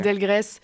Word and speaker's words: Delgrès: On passe au 21 mars avec, alors je sais pas comment ça se dit, Delgrès. Ouais, okay. Delgrès. On dit aussi Delgrès, enfin, il y a plusieurs prononciations, j'ai cherché déjà sur Google et, okay, en fait il dit qu'on peut dire les deux.